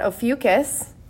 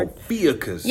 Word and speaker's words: Ophiuchus: So my notes say that I'm going to talk about Ophiuchus. Yes, you guys, if you look that up, the spelling does not give any indicator that it should be pronounced like that.